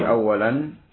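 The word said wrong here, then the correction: Aula (أولى): Aula, bacanya bukan awalan, aula.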